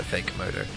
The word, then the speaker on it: Fake Modo: Killswitch Engage there is Fake Modo, who I, until very, very recently, as in probably this morning, kept mispronouncing as Fake Commando. They're not Fake Commando, they're Fake Modo.